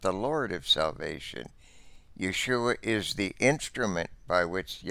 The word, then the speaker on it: Yeshua: As mentioned, the Hebrew word for salvation is Yeshua, with the accent on the last syllable, and is a feminine noun, but it is personalized in the masculine form of the Hebrew noun.